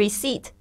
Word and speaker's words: receipt: 特别注意一下这个 receipt，它虽然里面有一个 P，但是 P 不发音，不要念成 receipt，不要念错了。